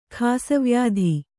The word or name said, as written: ಖಾಸವ್ಯಾಧಿ